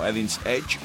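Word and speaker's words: Eden's Edge: amb el més nou de Edins Edgy. És una excel·lent formació, o Edins Edge, com també es pronuncia en segons quines contrades, amb l'àlbum del mateix nom, publicat aquest 2012, que inclou una cançó que es diu Swinging Door.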